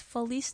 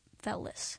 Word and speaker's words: Felice: I just looked up how to pronounce that, and it is Felice, not Felice.